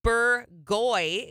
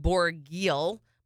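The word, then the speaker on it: Bourgueil: Bourgoy is the name of the region, even though it looks like Bourguil, but they pronounce it Bourgoy.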